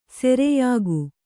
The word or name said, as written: ಸೆರೆಯಾಗು